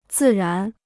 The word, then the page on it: 自然: zì rán